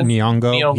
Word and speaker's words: Nyong'o: Nyong'o. – Nyong'o.